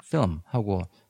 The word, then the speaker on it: film: 그다음에 또 나는 필름이라고 발음했는데 제러미는 film 하고 하나로 발음을 하는구나.